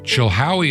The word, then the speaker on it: Chilhowie: Chilhowee is spelled C-H-I-L-H-O-W-I-E. Kind of like it sounds, Chilhowee.